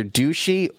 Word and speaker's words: Duchy: I think it's either D-U-C-H-Y. It's either Ducey or could be Duchy. – Duchy, as in pass it on the left-hand side.